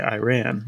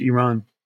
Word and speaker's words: Iran: Tell you what, Iran got very petty and even criticized the way we say Iran. They say, actually, it's pronounced Iran. Because apparently everybody on earth can have their own accent except Americans. Not allowed to say Iran, yet to say Iran.